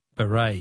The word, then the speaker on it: beret: Words that end with a silent T. Beret is B-E-R-E-T.